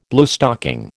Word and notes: Bluestocking: American English

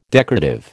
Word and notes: decorative: American English

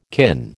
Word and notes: kin: American English

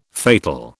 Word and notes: fatal: British English

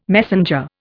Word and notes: messenger: Indian

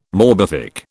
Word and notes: morbific: British English

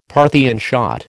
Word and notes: Parthian shot: American English

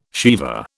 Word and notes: sheva: British English

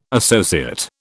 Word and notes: associate: British English

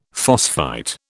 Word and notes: phosphite: British English

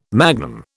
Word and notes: magnum: British English

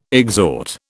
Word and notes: exhort: British English

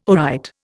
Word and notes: aright: Indian

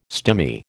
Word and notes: stimy: American English

